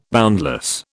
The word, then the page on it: boundless: British English